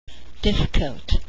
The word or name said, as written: difficult